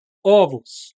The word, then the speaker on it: ovos: Ovo, ovos. No plural, você tem que pronunciar com O aberto?